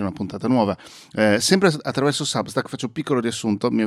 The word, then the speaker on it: Substack: Trovi anche questo podcast su Substack, scritto proprio Substack, si pronuncia ovviamente Substack,